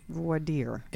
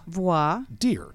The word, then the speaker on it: voir dire: Well, voir dire is spelled V-O-I-R. That's the first word. – Uh-huh. – And dire is spelled D-I-R-E. – Voir dire. – It's pronounced voir. – Voir. – Dire. – Dire.